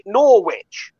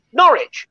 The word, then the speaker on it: Norwich: Got one of the stories tonight is from, and you pronounce it Norwich. – That's in Connecticut. Yeah. – The original town is in East Anglia, and it's in Norfolk, and it's Norwich. If you're in the UK, that town is Norwich, right? You've got exactly the same spelling. You've got it, you know, you clearly it's lifted from the, but you've changed the pronunciation.